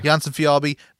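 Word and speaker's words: Jonsson-Fjällby: I got to see, how do you say the guy's name? Axel Fjällby. – Jansson Fjällby.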